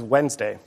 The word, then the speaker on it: Wednesday: Shakespeare's first folio spells this day of the week as it's said, without the first D. – What is Wednesday? – Yes.